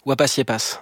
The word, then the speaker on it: Wapasiepas: enfin, l'absence de sens vous apaise, comme Ipapupapéis et Wapayepa. – Wapasiepas. – Ah, pardon. Wapasiepas. – On prononce le S.